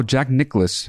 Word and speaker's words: Nicklaus: Is that how you pronounce it, really? – No, Jack Nicklaus. – That's what I said. – You said Jack Nicholas. – Oh. Jack Nicholas, I said. – No, there's no vowel.